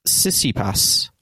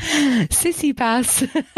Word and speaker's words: Tsitsipas: Stefanos Tsitsipas won the... I said that wrong, didn't I? I said it wrong, didn't I? – Tsitsipas. Sorry, carry on.